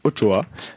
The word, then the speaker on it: Ochoa: Donc Francisco Guillermo Ochoa Magaña, plus connu sous le nom de Guillermo Ochoa, prononcé Ochoa, né le 13 juillet 1985 à Guadalajara, est un footballeur mexicain.